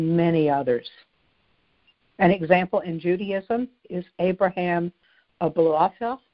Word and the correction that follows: Abulafia: I'm not sure I'm pronouncing his last name right. It's spelled A-B-U-L-A-F-I-A, Abulafia, who was a medieval Jewish mystic, and he was the founder of what is known today as prophetic Kabbalism.